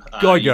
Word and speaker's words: Geiger: You nailed it the first time, Geiger.